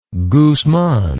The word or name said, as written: guzman